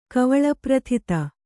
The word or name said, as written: ಕವಳಪ್ರಥಿತ